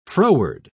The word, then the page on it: froward: British English